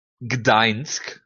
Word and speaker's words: Gdańsk: Gut, dann haben wir noch Main Event UFC Gdańsk, so heißt das. Ja? So spricht man das aus, hat mir ein äh, äh, äh Schlesier mal erzählt.